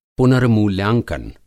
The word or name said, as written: पुनर्मूल्यांकन